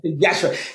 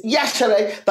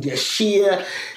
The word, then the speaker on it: Yashere: Yashere, because the I is almost, it's Yashere, the I is silent.